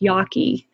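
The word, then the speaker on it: Jaki: It's J-A-K-I, but it's pronounced Yaki.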